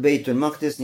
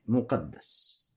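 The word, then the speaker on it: бейт-аль-Макдис (بيت المقدس): Первое это с фатхой буквы мим, то есть аль-Макдис. Хорошо? Бейт аль-Макдис. Это первый вариант. Второй вариант буква мим с ламмой и каф и даль с шаддой. Мукаддас.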